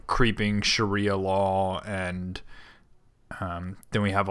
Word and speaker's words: Sharia: I'm sorry, so I will henceforth amend my pronunciation and Sharia. Call it Sharia.